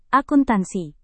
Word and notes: akuntansi: indonesian

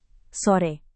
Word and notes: sore: Indonesian

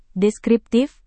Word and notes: deskriptif: Indonesian

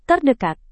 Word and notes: terdekat: indonesian

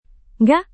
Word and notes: ngga: Indonesian

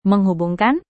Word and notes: menghubungkan: Indonesian

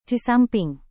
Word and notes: disamping: indonesian

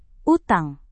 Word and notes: utang: Indonesian

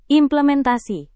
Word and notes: implementasi: Indonesian

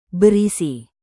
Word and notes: berisi: Indonesian

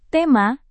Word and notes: tema: indonesian

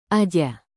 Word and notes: aja: indonesian

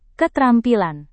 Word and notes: ketrampilan: indonesian

